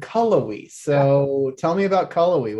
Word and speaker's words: Cullowhee: And how do we pronounce the town that Western Carolina is located in? Cullowhee, North Carolina. Cullowhee.